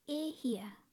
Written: E hia

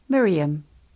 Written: Mirium

